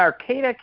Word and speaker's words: Arcata: I live in Arcata, California, behind the Redwood Curtain.